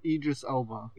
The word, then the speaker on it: Idris Elba: Eldris Elba. – Elba. I feel like an asshole because I can't pronounce his name. – Idris Elba. – Yeah. – Idris Elba, there you go.